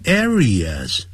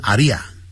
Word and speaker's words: areas: 很不幸的呢叫 unfortunately, 啊那个 t, 不要念得很强，不要念成 unfortunately, 这样子 unfortunately, even today, 甚至在今天 in some areas, 啊不要念成阿利亚，我在上海听到有一个人念成阿利亚，听得蛮难过的。